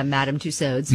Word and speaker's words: Madame Tussauds: Is that how you say it, Madame Tussauds? I always forget. – Yeah, Madame Tussauds.